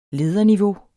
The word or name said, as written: lederniveau